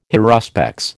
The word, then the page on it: haruspex: American English